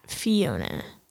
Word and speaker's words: Fiona: I've been saying my name kind of weird in the last episode, and then I just said it weird again. I'd like to address that my name is actually pronounced Fiona.